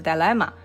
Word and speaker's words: dilemma: 呃，我们上一期其实聊到那个社交困境，它的那个英文的准确发音应该是 dilemma。 social dilemma，我我上次的发音不准确，我就不重复了。